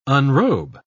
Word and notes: unrobe: British English

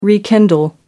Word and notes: rekindle: American English

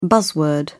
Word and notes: buzzword: British English

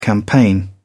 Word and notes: campaign: British English

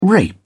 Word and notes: rape: British English

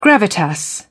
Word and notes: gravitas: British English

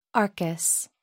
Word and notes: arcus: American English

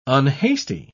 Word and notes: unhasty: British English

